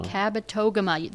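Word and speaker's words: Kabetogama: When you see that word, you will say, how do you pronounce that? Mm-hmm. Cabotogama. The word toga is in the middle.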